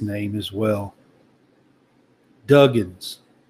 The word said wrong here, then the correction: Duggan: Is that right? – Max Duggan.